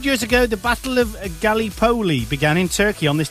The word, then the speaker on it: Gallipoli: Gallipoli. – Gallipoli.